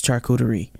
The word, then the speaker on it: charcuterie: From crowd-pleasing charcuterie boards, that's how you say it, you hear me, people? It's charcuterie. To photo-worthy desserts, it's easy to add these tasty party pleasers to your weekly order, saving you so much time.